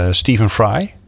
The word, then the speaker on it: Stephen Fry: Mythos van um, Stephen Fry. Zeg ik dat goed? Ja.